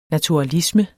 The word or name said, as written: naturalisme